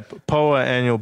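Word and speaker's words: Poa annua: Poa. How do you say it? Poa annual? – Yeah. Poa annual bluegrass.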